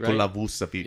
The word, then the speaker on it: Nikola Vucevic: Or then the guy in the Bulls, Jusevic, right? Nikola Vucevic.